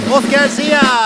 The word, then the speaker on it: Oscar Zia: Och här är han nu, den skånska svärmorsdrömmen, den stjärnsjungande, radiopratande idolen, Oscar Zia!